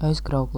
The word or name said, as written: Aizkrauke